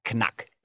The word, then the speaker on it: Knack: K-N-A, this is not a knack joke, but K-N-A-C-K. Oy. – K-N-A-C-K, knack. No. – But it has the word itself, because they pronounce the K, it's not a silent K, it has a click sound to it, right? Knack.